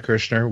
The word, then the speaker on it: Kirchner: I'm at Chad Kirshner, which my last name is spelled K-I-R-C-H-N-E-R.